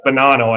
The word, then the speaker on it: Bonanno: Mafia, Lucchese family, the Bonino family. That's Bonanno, I guess is how it's pronounced.